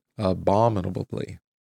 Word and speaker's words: abominably: Yeah, that's how you say it. I think if you put the accent on the right syllable, you get it right. Abominably.